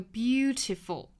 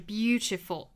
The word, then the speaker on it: beautiful: 那回到我们早读里面的这个 beautiful，英式发法是 beautiful，美式要发成 beautiful，也是轻弹而过 beautiful， beautiful。